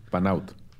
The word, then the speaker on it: Panaut: ¿Cómo se pronuncia el Panaut? Panaut. Panaut. O sea, lleva acento en la primera A.